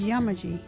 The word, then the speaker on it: Yamaji: local Wajiri word is Yamaji.